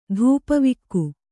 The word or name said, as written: ಧೂಪವಿಕ್ಕು